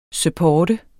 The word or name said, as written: supportere